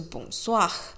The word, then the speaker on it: Bonsoir: 然后，所以如果是晚上的话，就记得大家要记得就是 Bonsoir，嗯，那个 R 要发音哦，就是对。就是其实还蛮特别的，就是你知道发文呢，就是子音放在尾巴的时候是不发音的，但有些东西是比较特别的，像 soir s o i r 这个 r 呢就有发音，所以你就听到大家都会把 r 发出来，大概就是这样。可是大部分的时候呢，大部分的字呢尾音是不发的。